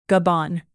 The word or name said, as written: gabon